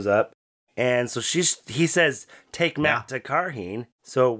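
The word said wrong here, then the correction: Cairhien: Yeah, it's pronounced Kyrian. – Kyrian. – Yeah, they said it in the show. I was like, oh, that's how you say it.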